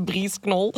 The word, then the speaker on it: Breeze Knoll: Breeze Knoll. – Of Breeze Knoll. Zo de silent... – Ah, is het de K is silent. – Ja, kan. Breeze Knoll of Breeze Knoll? – De Breeze Knoll.